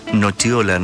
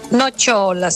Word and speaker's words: nocciola: Y se pronuncia nocciola, nos dice por acá Daniel.